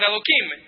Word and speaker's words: Elohim: Elohim, you have to pronounce it Elohim.